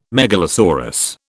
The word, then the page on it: megalosaurus: British English